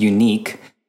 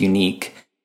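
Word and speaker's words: Unik: The other thing to point, so just on the tooling landscape here, there is kind of a newish tool out there it's pronounced Unique.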